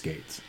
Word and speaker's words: Gaetz: It's Gaetz.